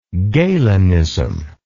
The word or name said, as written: galenism